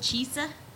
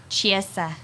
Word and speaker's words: Chiesa: Michael Chiesa? – Definitely not. – How do you say it? – Chiesa. Get closer to the mic. – Chiesa. – There you go.